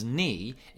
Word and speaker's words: knee: So lamb is spelt L-A-M-B. But you don't pronounce the B at the end, and knee is K-N-E-E, but we don't say the K at the beginning.